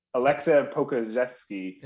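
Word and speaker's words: Aleksej Pokuševski: And I'm sure a lot of people that haven't followed the Thunder too well, Poku, it's like Alexev. – Alexei Pokuzevsky. – There you go.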